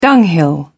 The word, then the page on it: dunghill: British English